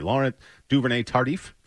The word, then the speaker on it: Laurent Duvernay-Tardif: It is Laurent, I want to keep, I hope I'm pronouncing this correctly, Laurent Duvernay-Tardif? – Yeah, that's right.